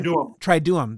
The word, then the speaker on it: Triduum: And that was the beginning of, and correct me if I got the name wrong, was it the Triduum? The beginning. – Triduum. – There you go.